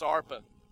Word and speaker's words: Orpah: The name of the one was Orpah. Actually, it's pronounced Arpa. I got that one right, Arpa.